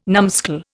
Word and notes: numskull: Indian

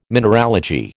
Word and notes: mineralogy: American English

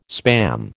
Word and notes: Spam: American English

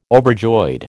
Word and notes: overjoyed: American English

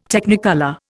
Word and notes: Technicolor: Indian

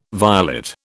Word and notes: violet: British English

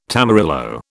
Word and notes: tamarillo: British English

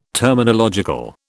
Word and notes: terminological: British English

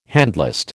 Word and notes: handlist: American English

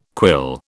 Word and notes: quill: British English

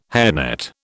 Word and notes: hairnet: British English

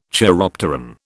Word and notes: chiropteran: British English

